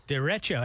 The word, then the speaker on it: derecho: In English, it is said as derecho for reference in Spanish. Derecho. – Derecho. – Derecho.